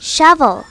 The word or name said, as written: shovel